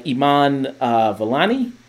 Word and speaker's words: Iman Vellani: Kamala Khan is the superhero of Ms.Marvel that you have here, who's played by Iman Vellani.